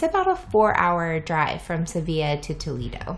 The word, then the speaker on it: Sevilla: But really, so Seville, a lot of people would say Seville because it's spelled S-E-V-I-L-L-E. – So Seville and Sevilla, they're actually the same. – Yes, but Sevilla is the right way to say it. – They say it Sevilla.